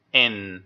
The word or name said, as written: in